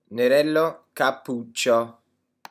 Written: nerello capuccio

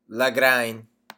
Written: lagrein

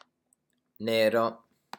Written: nero

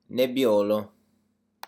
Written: nebbiolo

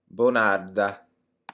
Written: bonarda

